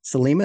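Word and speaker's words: Sliema: I noticed that there's a lot of waterfront construction going on in Salima. Is that correct pronunciation? – Yes.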